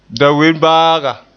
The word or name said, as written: dawenbaaga